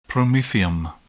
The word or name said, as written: promethium